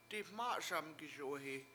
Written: diMA7SamgiSuwe7i

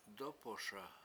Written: dOpoSa

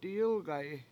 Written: diyIlgayi7

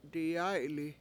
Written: diyAy7li

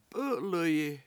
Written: p7!7l1yi

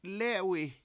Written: lEE7wi

